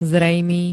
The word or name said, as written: zrejmý